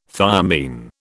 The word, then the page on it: thiamine: British English